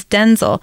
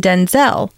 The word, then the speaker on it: Denzel: During a September 2nd appearance on Jimmy Kimmel Live, the actor, who's 70, told the host that his name is Denzel, with a clipped E sound rather than Denzel.